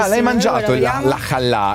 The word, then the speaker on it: challah: Cioè, se tu non sai pronunciare shallah, non è che mi sembra che è challah.